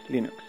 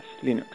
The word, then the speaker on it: Linux: Linus Torvalds, and I pronounce Linux as Linux as Linux.